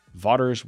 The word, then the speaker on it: Vaughters: Thank you for joining us for today's episode with none other than the legendary Jonathan Vaughters. That's Vaughters, which is spelled like daughters, but with a V, in case you were wondering.